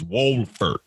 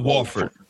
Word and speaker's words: Wolford: He said it's Wolford, not Wolford. Wolford, yeah, W-O-L-F-O-R-D. – There's an L in there. – Yeah, it's an L-E. It's a hard L-E. Wolford.